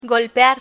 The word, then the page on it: golpear: Spanish